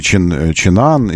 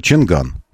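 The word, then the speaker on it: Changan: И она черным по белому сказала «Чинган». Все. Вот как написано, так и произносится, чтобы никому не приходилось ничего придумывать. «Чинган» написано, «Чинган» произносится. Это вам не французы, у которых «Ренаут» написано, а «Рено» нужно произносить. Или «Пиугиот» написано, а «Пежо» нужно сказать. Нет, здесь «Чинган» написано, «Чинган» и говорите.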